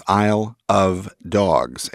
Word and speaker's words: Isle of Dogs: Nothing is real in Wes Anderson's Isle of Dogs, a movie whose title you have to pronounce carefully or it turns into I Love Dogs, which, after you see the film, will almost certainly be true.